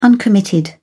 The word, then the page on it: uncommitted: British English